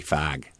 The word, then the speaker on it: fog: a very frequent word you pronounce is F-O-G, you know, which in Northern California is like fog. And in Chicago, it'd be fag.